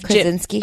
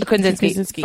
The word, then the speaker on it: Krasinski: Krasinski. – Krasinski.